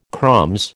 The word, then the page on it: crumbs: American English